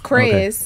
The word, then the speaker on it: Kris: I also don't want them to say it wrong. So it's just Kris.